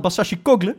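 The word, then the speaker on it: Başacıkoğlu: Wesley, Bilal Basashikoglu, moet ik zeggen, toch? Zo spreek je dat uit? Kogle. Ja, oh, die goal, ja, die heb ik gezien.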